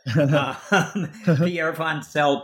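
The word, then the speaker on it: Wantzel: You mentioned that Pierre Wansel, now I know how to pronounce it, Pierre Wansel proved three of the four problems were impossible.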